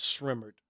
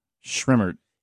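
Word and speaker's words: Sremmurd: Well, it's Ear Drummers backwards. – Okay. – So Ear backwards is Ray, and then Drummers backwards is like S-R. You know, so it's pronounced Shrimmered. – Shrimmered.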